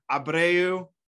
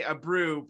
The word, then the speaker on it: Abreu: but we now have with us Jorge Abreu. – You nailed it, man. Yeah. – All right. Look at that. He gave me permission to say Abreu, but I feel bad.